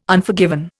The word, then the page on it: Unforgiven: Indian